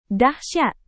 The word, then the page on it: dahsyat: Indonesian